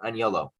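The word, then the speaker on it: Anyolo: Philip Onyolo is spelled A-N-Y-O-L-O. A-N-Y-O-L-O, Onyolo.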